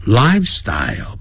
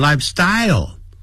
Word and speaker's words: lifestyle: 好，lifestyle 不要念成 lifestyle，重音在第一个音节，style 因为有 l 结尾，所以舌尖要抵上来，像发出欧洲的 o 的音，style。